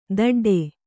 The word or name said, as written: ದಡ್ಡೆ